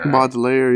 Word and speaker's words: Baudelaire: He also loves poetry, specifically uh, Baudelaire. Is that how you pronounce it? – Uh, Baudelaire? – Uh, Baudelaire? – Baudelaire, yeah.